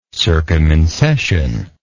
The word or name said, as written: circumincession